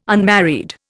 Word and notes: unmarried: Indian

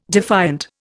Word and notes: defiant: Indian

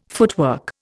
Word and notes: footwork: Indian

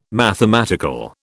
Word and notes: mathematical: British English

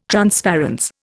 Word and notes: transparence: Indian

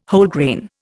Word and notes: Wholegrain: Indian

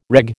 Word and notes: reg: American English

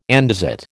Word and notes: andesite: American English

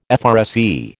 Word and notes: FRSE: American English

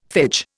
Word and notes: phage: Indian

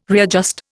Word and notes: readjust: Indian

